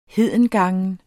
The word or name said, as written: hedengangen